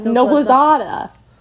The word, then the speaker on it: Noblezada: Is it Noblezada? – Noblezada. – No, it's Noblezada.